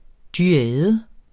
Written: dyade

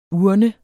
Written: urnens

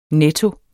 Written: netto

